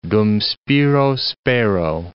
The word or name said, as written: dum spiro, spero